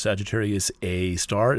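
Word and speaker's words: Sagittarius A*: The galactic center, I count that as the supermassive black hole at the center of the galaxy called Sagittarius A star, written Sagittarius A asterisk, but pronounced Sagittarius A star.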